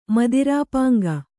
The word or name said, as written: ಮದಿರಾಪಾಂಗ